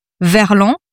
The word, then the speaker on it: verlan: Can you explain what verlan is? Have I pronounced that right? Verlan? – Yes, correct.